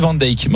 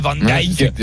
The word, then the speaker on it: van Dijk: Oui, parce qu'on dit van Dijk. Mais regarde, on dit pas van Dijk. On dit van Dijk.